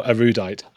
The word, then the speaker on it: erudite: Yeah, they're much smarter than me, first, and certainly more erudite. I don't even know how to pronounce it. – Erudite. – Where would the accent be if it was Spanish? It would be on the first letter, erudite.